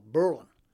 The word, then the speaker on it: Berlin: What I found is that the Yankees that first came here, once they changed the name from Mainsboro to Berlin, their pronunciation was on the first syllable, Berlin, and not on the second syllable.